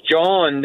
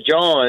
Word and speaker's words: John: Actually, it's John down here. – You're in the parish. – In the parish, yeah, John. It's not John.